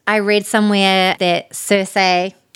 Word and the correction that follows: Saoirse: Wait, is that how you pronounce her name? – Saoirse.